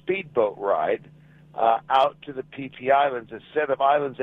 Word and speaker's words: Phi Phi: It is pronounced the Phi Phi Islands.